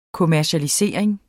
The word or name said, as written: kommercialisering